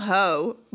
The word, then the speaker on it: Haux: ATL Ho, but Ho is spelled H-A-U-X.